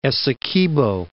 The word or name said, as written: Essequibo